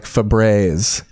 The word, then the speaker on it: Febreze: No, it's F-E-B. – Oh, they don't know. R-E-Z-E. – Okay, interesting. – It's Febreze. I don't think there's no E. There's no long E. It's like Febreze.